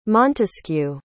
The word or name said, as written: montesquieu